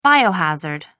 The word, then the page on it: biohazard: American English